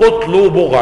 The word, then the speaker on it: قطلوبغة: حاشية نزهة النظر لابن قطلوبغة، قطلوبغة المتوفى سنة 79 و800 ومخطوط. قطلوبغة هكذا نطقها بضم القاف وتسكين الطاء وضم اللام وتسكين الواو وضم الباء وفتح الغيم وتسكين الألف، طبعا كلمة تركية.